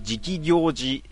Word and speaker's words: 直行寺: 直行寺と書いて直行寺って読むらしいんですけども、宇治市にあるらしいですよ。